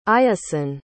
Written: iassen

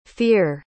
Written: fihr

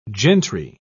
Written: gentry

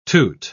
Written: toot

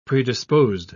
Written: predisposed

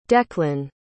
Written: declan

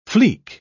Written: flic

